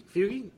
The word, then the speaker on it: Fuge: Right now, I have the uh, the wonderful Matthew Vercant and Pat Fuge Fugi? Fugi? Did I pronounce that correctly? you got it right, Fugi. Yep. Anything but fudge is good.